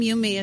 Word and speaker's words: Youme: They are in the library, and my name Yumi is spelled Y-O-U-M-E.